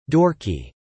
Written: dorky

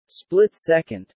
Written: split-second

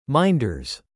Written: minders

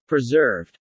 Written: preserved

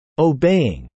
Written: obeying

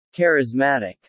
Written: charismatic

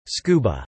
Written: scuba

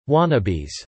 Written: wannabes

